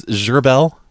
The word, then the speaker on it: Gerbil: That's Gerbel. – How you spell that, Roy? – T-H-A-T. – How do you spell your last name, Roy? – Oh! G-E-R-B-I-L. – Z-E-R? – Uh-huh, B-I-L. It's French.